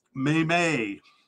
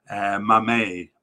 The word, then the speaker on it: Mmaee: And in the last podcast, I had no idea how to say his last name because it's spelled M-M-A-E-E. You try to say it. Go ahead, try to say it. Yes, you can you? Well, I couldn't either until the African Cup, where I saw that they pronounced it Maymay, Sammy Maymay.